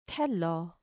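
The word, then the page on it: θέλω: t h ɛ l ɔː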